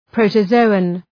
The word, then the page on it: protozoan: ,prəʋtə’zəʋən